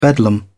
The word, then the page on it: bedlam: British English